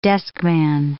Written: deskman

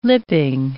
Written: lipping

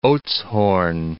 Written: Oudtshoorn

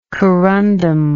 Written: corundum

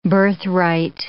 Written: birthright

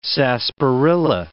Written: sarsaparilla

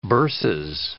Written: bursas

